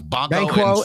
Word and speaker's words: Banquo: Banquo and Fleance.